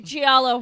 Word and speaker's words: giallo: How you pronounce it? Giallo? – Giallo horror. – Yes.